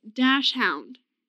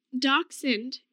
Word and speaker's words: dachshund: But the way people pronounce dachshund, the dog, so many people pronounce it dash hound.